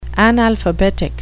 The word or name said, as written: analphabetic